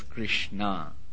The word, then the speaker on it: Krishna: That's why her name also was Krishna, not Krishna. Actually, name of Krishna ends with a short A, and Draupadi's name ends with a long A.